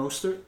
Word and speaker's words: Mostert: Mostert? – Yeah, Raheem yeah. Mostert.